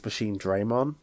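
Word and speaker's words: Machinedramon: But that's not how you pronounce it. It's Machine Draymon.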